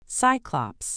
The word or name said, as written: cyclops